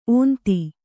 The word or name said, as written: ಓಂತಿ